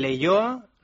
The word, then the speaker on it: Leioa: Felicidades a 9 equipos, el Mallorca B, el Marbella, el Leioa, ¿lo he dicho bien, Joseba? – Sí.